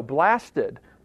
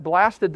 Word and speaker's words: blasted: No, blasted my feelings. But blasted, the accent's on the wrong syllable. he even makes a little mark, like in the, in the original text, with like an accent.